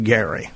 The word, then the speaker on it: Geary: G-E-A-R-Y. They pronounced it Gary. Some say Geary.